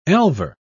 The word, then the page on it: elver: British English